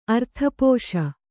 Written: ಅರ್ಥಪೋಷ

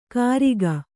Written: ಕರಿಗ

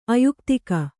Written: ಆಯುಕ್ತಕ

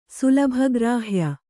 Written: ಸುಲಭಗ್ರಾಹ್ಯ

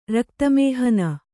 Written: ರಕ್ತಮೇಹನ

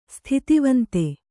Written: ಸ್ಥಿತಿವಂತೆ

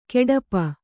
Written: ಗೆಡಪ

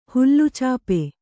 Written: ಹುಲ್ಲುಚಾಪೆ

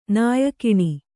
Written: ನಾಯಿಗಣ್ಣು

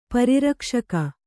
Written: ಪರಿರಕ್ಷಕ

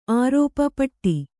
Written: ಪೋಟು

